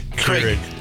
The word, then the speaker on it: Keurig: Krieg. – Keurig. – Keurig. – Yeah. You don't even know how to say it.